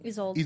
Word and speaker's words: Isolde: Isolde. – Isolde. Isolde. – See, that's how I dialed in. I went to this. – It's pronounced like I-Z, like Isolde.